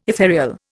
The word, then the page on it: ethereal: Indian